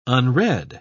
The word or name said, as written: unread